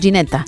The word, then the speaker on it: Ginetta: Sí, decimos Ginetta. – Ginetta. Qué nombre más bonito, ¿eh? – Es muy bonito. – Sí. – Es como gelato, pero Ginetta.